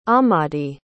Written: ahmadi

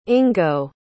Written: ingo

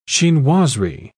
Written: chinoiserie

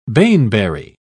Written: baneberry

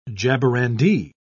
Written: jaborandi

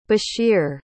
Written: bashir

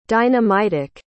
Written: dynamitic

